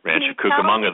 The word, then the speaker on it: Rancho Cucamonga: I think it's Rancho Cucamonga. I remember you Rancho Cucamonga. You may. – Did I say it right? – Rancho Cucamonga.